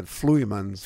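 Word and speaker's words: Vloeimans: Yeah, we say that Flumens. – Flumens, spelled with a V.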